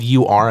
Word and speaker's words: Ur: What's pronounced, it's spelled U-R. I think it's pronounced Ur.